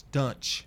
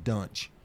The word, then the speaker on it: Duntsch: D-U-N-T-S-C-H, Dunch. I guess they pronounce it Dunch.